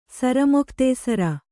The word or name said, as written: ಸರಮೊಖ್ತೇಸರ